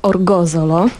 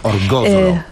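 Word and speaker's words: Orgosolo: Opowiedz nam zatem o swoich wrażeniach sardyńskich, bo już słyszałem dzisiaj w porannej audycji sporo, natomiast masz dla nas wizytę w Orsolo, prawda? – Tak, w Orgozolo. – Orgozolo. – Tak, ciężka nazwa. Mnie zajęło kilka dni, żeby nauczyć się wymawiać. Mam nadzieję, że jest poprawnie. Powinno być już poprawnie.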